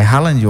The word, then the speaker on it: Haaland: E é Haaland ou Holland? – É Haaland.